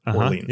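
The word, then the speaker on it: Orléans: is called Orleans. – Really? – And it is actually, that is the right way to say it here. Orleans.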